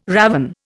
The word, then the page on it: ravin: Indian